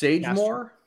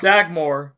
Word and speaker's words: Sagamore: I think it's pronounced Sagemore. I think it's not. It's not. – Sagamore.